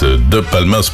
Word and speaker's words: Palmas: C'était au bord de l'eau d'El Palmas, de Palmas pour être exact. Voilà, et c'est mieux prononcé comme ça.